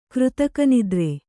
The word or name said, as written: ಕೃತಕನಿದ್ರೆ